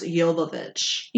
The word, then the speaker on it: Jovovich: I found out her last name is actually pronounced Jovovich. – Jovovich. I was close. Yeah. – No, you weren't.